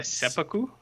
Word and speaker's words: seppuku: Like seppuku? – Thank you.